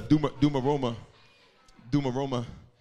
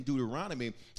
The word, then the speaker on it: Deuteronomy: I want everybody to say the word Deuteronomy with me, because sometimes when I hear Christians pronounce Deuteronomy, I hear things that are not Deuteronomy. Pepperoni pizza, Duma Roma, Giordano's. This is not a pizza.